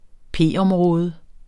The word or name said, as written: p-område